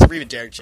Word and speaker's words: arrivederci: I gotta make fun of her all the damn time for that, because I didn't know how to pronounce it. It's Arrivederci.